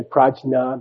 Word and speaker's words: Prajna: There is no J in Sanskrit. It's a Z. They don't say Raja, they say Radza. They don't say Prajna, they say Prajna.